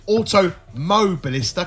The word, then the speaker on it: Automobilista: I've been told to pronounce it correctly on the forum. So Automobilista, because apparently I kept missing the mo. So I'm going to emphasise that going forward.